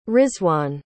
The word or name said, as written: rizwaan